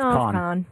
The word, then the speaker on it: Kahan: Oh, is it pronounced Kahan? – No, it's Kahan. – Oh, it's Kahan. – Well, K-A-H-A-N.